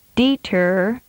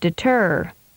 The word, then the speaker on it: deter: The most common mistake on this pattern is to put too much emphasis on the first syllable, as in these examples. – Deter instead of deter. Rebuke instead of rebuke. Decline instead of decline.